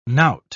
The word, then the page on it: knout: British English